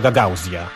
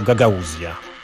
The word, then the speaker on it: Gagauzja: Ważne, żeby nie wymawiać tego Gagauzja, tylko Gagauzja.